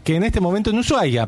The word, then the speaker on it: Ushuaia: Igual son creencias, porque viste, a veces de golpe entras, Ushuaia, sí. Que me encanta que se escriba SH, pero no se pronuncia, la H es muda.